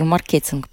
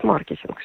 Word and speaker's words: маркетинг: А, русский язык не приемлет ударение на первом слоге, а в Латвии мы любим ударение на первый слог, поэтому, наверное, в Латвии чаще говорят маркетинг, а в России говорят маркетинг. Угу.